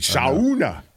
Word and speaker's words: sauna: Damn it, I forgot to pronounce it correctly. Sauna.